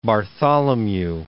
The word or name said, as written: Bartholomew I